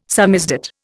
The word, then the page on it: samizdat: Indian